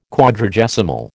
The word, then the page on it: Quadragesimal: American English